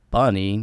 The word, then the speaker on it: bnny: You had a new one, actually, from, I believe it's pronounced Bunny, but it's written B-N-N-Y.